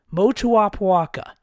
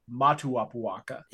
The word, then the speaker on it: Motuapuaka: Dave Fox, learn how to say his name. He's been at Utah State since 2018. Motuapuaka. It's not hard to say. – I, I would even say I'll accept Matuapuaka. – Yeah. If you're gonna say it wrong, at least say it phonetically.